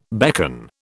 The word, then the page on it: beckon: British English